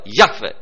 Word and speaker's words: Iahve: Se știe doar că cuvântul se pronunța dintr-o singură expirație. Ca și cum ar fi o singură silabă, Iahve, dintr-o suflare, dintr-o expirație se pronunța cuvântul acesta.